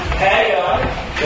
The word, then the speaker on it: Haggai: Now, I wanted to put this out there because there are two ways to pronounce the name of the book. Both of them are correct. So, if you say Haggai, you are correct. If you say Haggai, then you are correct as well.